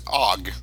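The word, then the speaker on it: Ogg: But then, if you go to the Mozilla area with Firefox, they're really pushing more of the AUG Theria format. I don't know if I said that right. – The VP8. – I think I think so. It's OG.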